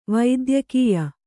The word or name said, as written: ವೈದ್ಯಕೀಯ